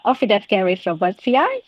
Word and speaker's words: Ophidascaris robertsi: It is a difficult one to pronounce, so it's Ophidascaris robertsi.